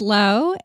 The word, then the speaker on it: Lo: Yeah, my name is Lo, and I'm also a podcaster, which is how we met.